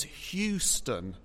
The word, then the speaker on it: Houston: The name of the US city Houston in American English has a silent O and a pronounced E. So one could spell it phonetically as Houston. Hugh as in, I will use my axe and hew this log into a beam. Stun as in, I will use my ray gun to stun the alien invaders.